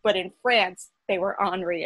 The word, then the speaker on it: Henri: except that Prince Henry should be Prince Henri, and King Francis should be King Francois.